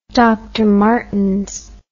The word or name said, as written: Dr Martens